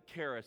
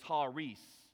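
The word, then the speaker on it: Haris (χάρις): We pronounce it Karis. That is not actually the way that you pronounce it, K-A-R-I-S. When it's talking about this right here, this gracious gift, it's talking about Karis. And the actual pronouncement is Haris, is how you say it.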